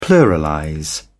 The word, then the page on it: pluralize: British English